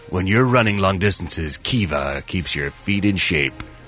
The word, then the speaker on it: Keeva: Kiva? – K-E-E-V-A.